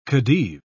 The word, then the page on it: khedive: British English